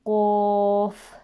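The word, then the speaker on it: Qaf (ق): It is very important to remember from the previous lessons that one letter is pronounced as Qaf because it is a full mouth heavy letter, and this one that we are studying at the moment is pronounced as Kaf because it is empty mouth letter.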